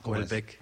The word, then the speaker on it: Houellebecq: Ajá, Houellebecq. – Houellebecq. Sí, Houellebecq.